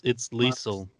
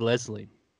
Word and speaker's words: Liesl: By the way, it's Liesl. It's Liesl. – What did I say? – You said Leslie. – I didn't say Liesl. – No, you said it wrong. – She's been on our show. – We had her on the show. She said it rhymes with diesel.